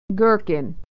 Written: gherkin

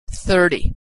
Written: 30

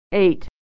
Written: ate

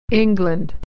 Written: England

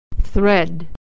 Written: the thread

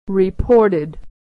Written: reported

